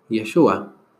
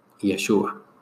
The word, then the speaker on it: Yeshua: Esto es porque en hebreo Jesús se dice Yeshua y se pronuncia así, Yeshua.